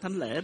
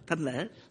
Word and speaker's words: Thanh Lễ: nhưng mà tên hội thánh không phải là thánh Thánh Lễ mà là Thanh Lễ.